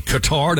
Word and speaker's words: Qatar: Qatar, did I say that correctly? – Yeah.